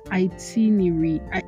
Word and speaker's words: itinerary: It's actually not pronounced itinerary. It's not pronounced itinerary. It is pronounced itinerary. Itinerary. Oh my God. Oh Lord. Somebody should help me. It's not pronounced itinerary. It's pronounced itinerary. Which one is even the original pronunciation? I found that the thing that shocked me about this one is the fact that it is not pronounced et but it.